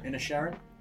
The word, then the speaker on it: Inisherin: How do you pronounce it? Inisherin? – Inisherin.